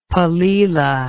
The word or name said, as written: palila